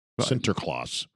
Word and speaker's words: Sinterklaas: It derived from the old Dutch word Sinterklaas. Sinterklaas. – Which is pronounced perfectly.